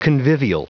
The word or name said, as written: convivial